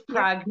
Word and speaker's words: Pranja: It's Pranja. Yeah. The J is pronounced like a G in Sanskrit.